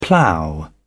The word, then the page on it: plough on: British English